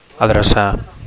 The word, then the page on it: adrecar: Catalan